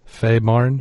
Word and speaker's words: Fehmarn: Feymar? F E H M A R N Marn Faymarn.